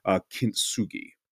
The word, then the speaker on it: kintsugi: So I'm not sure if you've ever heard of this, and I want to make sure I'm pronouncing it correctly, but I believe it's pronounced kintsugi.